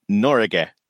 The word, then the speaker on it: Norge: How is Norge pronounced? – Okay, come on then. – It turns out it just means Norway in Norwegian, which is fair enough to write on the side of one of your helicopters, I suppose. – Yes. – And it's pronounced Norge.